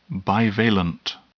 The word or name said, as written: bivalent